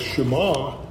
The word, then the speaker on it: Shema: It's spelled S-H-E-M-A, but it's pronounced Shema.